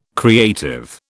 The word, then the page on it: creative: British English